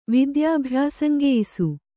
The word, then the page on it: ವಿದ್ಯಾಭ್ಯಾಸಂಗೆಯಿಸು: kannada; vidyābhyāsangeyisu